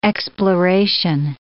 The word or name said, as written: exploration